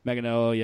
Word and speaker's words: Manganiello: Manganiello.